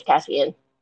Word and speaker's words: Cassian: I think you said it right. I think it's Cassian.